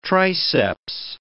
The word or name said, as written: triceps